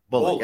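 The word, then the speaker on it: Bulloch: Bunch? – Bullock.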